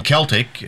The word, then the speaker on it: Celtic: And Celtic is spelled with the hard C. – Yes. – So it's C-E-L-T-I-C.